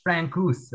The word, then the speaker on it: Francouz: Francouz.